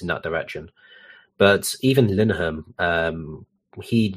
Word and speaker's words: Linehan: I think when you're talking about Graham Linehan, I can't pronounce his name, but... – it's just Linehan. That's all.